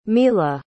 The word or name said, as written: mila